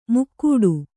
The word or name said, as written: ಮುಕ್ಕೂಡು